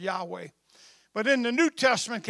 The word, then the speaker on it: Yahweh: It was actually pronounced Yahweh.